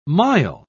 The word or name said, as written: mile